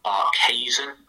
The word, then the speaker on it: Barkhuizen: He's South African descent. He said it's pronounced Tom Barkhuizen, apparently.